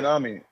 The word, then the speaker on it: Fujinami: I can't even say his name. For the Athletics. – Fujinami. – Thank you.